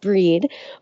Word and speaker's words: Brigid: It's also traditionally been the celebration of the goddess Brigid, pronounced Breed, where women would light candles and ask for their blessings for fertility and purification.